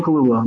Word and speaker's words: Kaluuya: Is the last name pronounced Kaluuya? – Yep, Daniel Kaluuya.